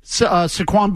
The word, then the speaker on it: Saquon: Fourth, Saquon Barkley. Did I say that right? – Yes.